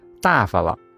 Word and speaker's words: 大发了: 四大发了，读音时在大发字读轻音，形容太过分了。